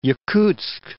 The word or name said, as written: Yakutsk